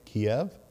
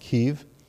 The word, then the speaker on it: Kyiv: The Russian occupiers did not manage to capture Kiev, or they pronounce it Kyiv,